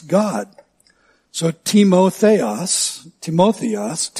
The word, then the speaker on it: Timotheos: If you're taking notes, it's spelled in the Greek, uh, in the transliteration from the Greek, just the same way we spell the word time, T-I-M-A, but it's pronounced time.